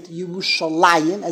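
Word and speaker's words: Yerushalayim (ירושלים): And that's why there's only really, even though we pronounce it Yerushalayim, as if there is a Yud to indicate there are two Yerushalayims, but it's spelled Yerushalayim without the Yud to indicate there's really only one Yerushalayim.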